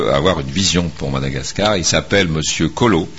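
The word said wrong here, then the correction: Kolo: Oui, Koulou. – Donc, s'il a une vision, c'est Monsieur Coloscopie. – On prononce Koulou.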